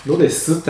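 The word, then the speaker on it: Rodez: 15 ステージは、えー、ロデ、ロデスって発音だからね。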